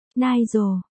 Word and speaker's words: Nigel: Ô, chú thích, Nigel, đọc là, Nigel, nha, cách chương.